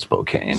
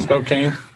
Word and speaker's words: Spokane: Call it Spokane. – Spokane. Yeah. That's why it's spelled.